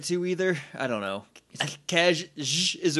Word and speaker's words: Casje: Yeah, that picture for Cash. Which, if you don't know how to pronounce C-A-S-J-E, it's Cash.